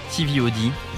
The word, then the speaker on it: TVOD: Il faut le dire avec la prononciation avec anglaise. – Donc un concert de TVOD.